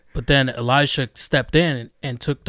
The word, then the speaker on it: Elisha: I'm I'm sorry, I don't know if it's Elisha, Eliseo. Is that how you say it? – Elisha. Elisha. Eliseo so Elijah Elisha was only with Elisha for a season.